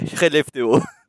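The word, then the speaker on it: Skellefteå: Parce que vraiment, en suédois, il dirait Relefteå.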